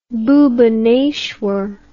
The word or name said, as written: Bhubaneswar